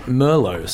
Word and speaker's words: Merlot: Which we actually, in Australia, we pronounce it Merlot. So we put an emphasis on the different syllables.